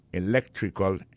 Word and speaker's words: electrical: Electrical engineering. Ça veut dire accent tombé dans electrical là, c'est sur deuxième syllabe là. Electrical engineering. C'est sur deuxième syllabe là. Elect là, plus appuyé.